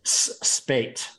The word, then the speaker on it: Spaight: Spate.